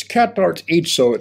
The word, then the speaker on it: katartizo: It's katartizo. It's spelled in English K-A-T-A-R, katar, then T-I-Z-O, katartizo.